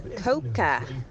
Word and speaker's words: Koka: Good morning, lads. It's pronounced Koka.